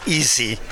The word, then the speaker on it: EASY: How do you pronounce that acronym? – We use EASY. Although it's not really an easy project, but that's the way the letters worked out, so we're stuck with it.